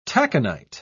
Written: taconite

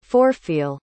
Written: forefeel

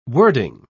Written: wording

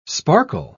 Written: sparkle

